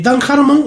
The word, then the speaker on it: Dan Harmon: ¿dije Dan Harmon? ¿Lo dije bien? – Dan Harmon, sí.